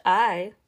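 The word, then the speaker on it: ae: And you have to ask yourself, well, they're named I, spelled A-E, but pronounced I, like the letter I, for a reason, because it makes you stop and think.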